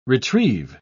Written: retrieve

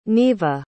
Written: neva

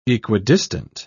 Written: equidistant

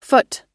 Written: phut

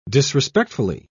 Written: disrespectfully

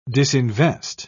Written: disinvest

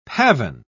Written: pavane

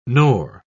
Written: nor

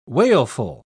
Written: wailful

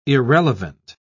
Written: irrelevant

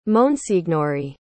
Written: monsignori

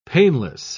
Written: painless

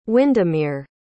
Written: Windermere

